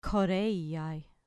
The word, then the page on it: choreiai: grec